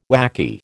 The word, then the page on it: whacky: American English